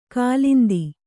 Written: ಕಾಲಿಂದಿ

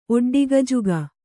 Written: ಒಡ್ಡಿಗಜುಗ

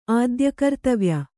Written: ಆದ್ಯಕರ್ತವ್ಯ

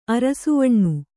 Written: ಅರಸುವಣ್ಣು

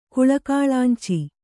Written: ಕುಳಕಾಳಾಂಚಿ

